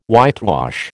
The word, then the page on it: whitewash: American English